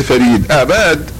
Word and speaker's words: فريد آباد: وأباد كلمة فارسية وتنطق بتفخيم الباء أباد ولا ينطقون أباد وإنما فريد أباد هكذا ينطق بها الفرس واما اهل الهند فهم يقولون فريد اباد فقط وهي فريد اباد مدينه